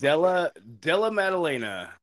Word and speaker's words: Della Maddalena: Della Maddalena.